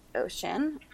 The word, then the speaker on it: Oisin: So my main character's name is Ocean, although it's spelled O-I-S-I-N.